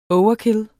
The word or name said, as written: overkill